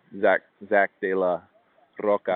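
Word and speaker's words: Zack de la Rocha: Like, Zach De La Roca, I think is how you say his name.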